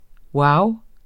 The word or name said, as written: wow